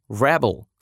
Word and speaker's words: rebel: Еще одна пара слов, в которых еще и меняется произношение, это rebel, существительное с ударением на первый слог, мятежник, мятеж, бунтовщик, бунт.